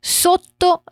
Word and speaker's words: sotto: Sotto la mole. Aqui a gente tem dois T's em sotto, então a gente isola esses sons para treinar a pronúncia.